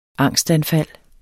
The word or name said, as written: angstanfald